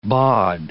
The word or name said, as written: baud